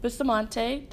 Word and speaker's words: Bustamante: I'm here with Nick Bustamante.